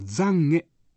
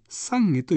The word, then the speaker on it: 懺悔: 一般には懺悔と読みますが、仏教では濁らずに懺悔と読みます。